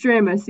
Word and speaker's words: Dramis: It's Dramis. You got it right.